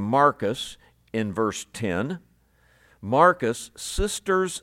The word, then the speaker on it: Marcus: But Marcus, of course, is the Greek pronunciation of that name.